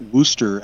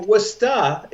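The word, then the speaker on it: Worcester: Like, how do you get Wooster out of all those letters? There's an R in there. – Or Wusta if you're a real native.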